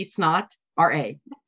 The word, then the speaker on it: Snotra: Is it spelled like snot or like Sinatra? – It is spelled like S-N-O-T, snot, R-A. Snotra.